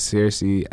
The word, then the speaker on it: Searcy: Then my last name is Searcy, SEARCY.